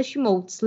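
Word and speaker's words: Schmolz: E nesses três lugares vocês me encontram pelo meu nome, que é Fernanda Schmoltz. Lembrando que meu sobrenome se escreve S-C-H-M de Mickey O-L-Z.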